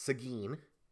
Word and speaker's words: Seguin: It's not Seguin, it's Seguin.